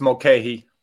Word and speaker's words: Mulcahy: It's Mulcahy.